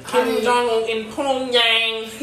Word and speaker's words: Pyongyang: It's Pyongyang. – Oh, for North Korea, maybe like, Pyongyang.